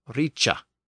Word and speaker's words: rice: So in Old English, we have the word R-I-C-E, which meant great or wealthy. But it was not pronounced like rice in modern English. Instead, the CH sound had emerged in this context, and the word was pronounced richa.